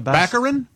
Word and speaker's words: Baccarin: Daredevil's girlfriend, Morena Baccarin. – Baccarin.